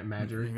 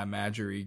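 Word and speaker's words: Marjorie: I don't even know how to say her first name. – Majory. – Yeah, Majory Green.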